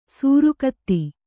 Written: ಸುರಗತಿ

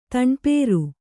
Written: ತಣ್ಪೇರು

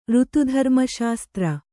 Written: ಋತುಧರ್ಮಶಾಸ್ತ್ರ

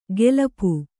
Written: ಗೆಲಪು

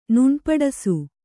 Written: ನುಣ್ಪಡಸು

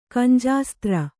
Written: ಕಂಜಾಸ್ತ್ರ

